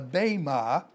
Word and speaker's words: bema: The Greek word is bema, B-E-M-A, the E is pronounced as a long A, bema, in Romans chapter 14, verse 10 through 12.